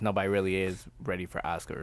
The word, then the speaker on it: Asuka: Yeah, he had tweeted like, oh, I guess they're scared that Asuka, did I say it right this time? – Yeah.